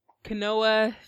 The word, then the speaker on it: quinoa: And the chips I had was the what's the Kuana chips? – Quinoa chips from Costco.